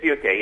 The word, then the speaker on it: Funke: I mean, I'm thinking both of your character Tobias Funk am I saying that right? Funke, that is, in Arrested Development. – It is Funke, yeah.